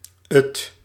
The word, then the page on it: 't: /ət/